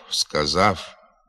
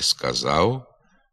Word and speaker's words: сказав: Вимова «пішов», «сказав», «львів», «навпаки», «вперше» є ненормативною. Треба говорити «сказав», «львів», «навпаки», «вперше».